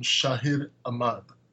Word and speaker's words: Shahid Ahmad: We did Unchained earlier, and we got to interview none other than Sony's own Shahid Ahmad.